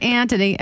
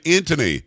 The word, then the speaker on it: Antony: See, you're saying Antony. It's not Antony. It's Antony. – Oh, Antony. Oh, yeah. Antony. – You have to Blinken. – Say it like a New York or New Jersey person.